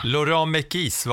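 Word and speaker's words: Laurent Mekies: Laurent Mekis var uttalet där. Vi dividerade lite om hur man uttalade hans namn för några avsnitt sedan, Johanna, men det var ju skönt att vi fick det på pränt där.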